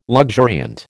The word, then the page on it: luxuriant: American English